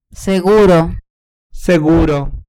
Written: seguro

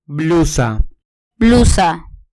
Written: blusa

